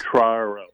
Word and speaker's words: Quattraro: Quattraro.